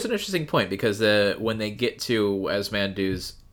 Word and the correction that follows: Ramandu: Ramandu. I said his name wrong before.